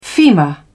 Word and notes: femur: British English

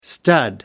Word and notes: stud: American English

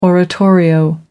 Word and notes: oratorio: American English

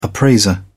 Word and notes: appraiser: British English

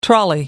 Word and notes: trolley: American English

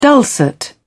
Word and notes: dulcet: British English

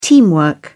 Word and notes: teamwork: British English

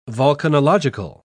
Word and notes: volcanological: British English

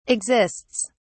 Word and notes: exists: British English

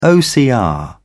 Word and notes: ocr: British English

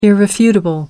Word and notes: irrefutable: American English

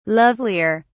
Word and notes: lovelier: British English

